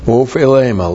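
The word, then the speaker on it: Ophelema (ὀφείλημα): Ophelema looks like that in the Greek. O-P-H-E-I-L long E-M-A.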